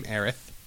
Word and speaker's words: Aerith: We have Aerith, or as she's referred to in the game, Aerith. – Yes. – That's not me talking with a lisp. That is the right way to say the name.